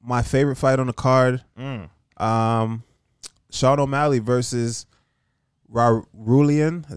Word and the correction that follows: Raulian: Is that how you say his name? – Haulian Paiva? – Yeah, Haulian.